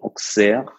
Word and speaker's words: Auxerre: Die Stadt wird ja mit X ausgesprochen, Auxerre.